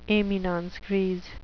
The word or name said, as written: eminence grise